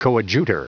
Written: coadjutor

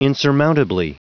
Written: insurmountably

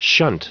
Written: shunt

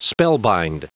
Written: spellbind

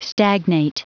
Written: stagnate

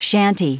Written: chantey

